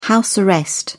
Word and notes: house arrest: British English

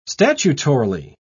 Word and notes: statutorily: British English

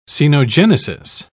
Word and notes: cenogenesis: British English

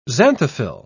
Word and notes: xanthophyll: British English

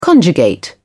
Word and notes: conjugate: British English

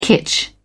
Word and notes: kitsch: British English

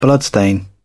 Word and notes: bloodstain: British English

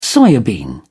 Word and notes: soya bean: British English

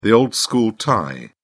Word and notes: old school tie: British English

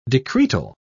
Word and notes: decretal: British English

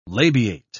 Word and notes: labiate: British English

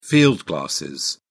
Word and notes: field glasses: British English

